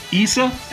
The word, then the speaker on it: Issa: So we will see you next week for Issa. Am I pronouncing it right now, Darren? – Correct.